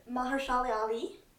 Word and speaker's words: Mahershala: Mahershala, is that how you say it? – Mahershala Ali.